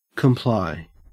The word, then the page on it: comply: Australian English